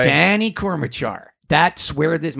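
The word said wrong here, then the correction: Kortchmar: Am I saying that right? – Korchmar. Yeah.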